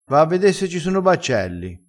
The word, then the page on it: e mi preme: dialettale